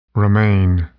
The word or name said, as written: remain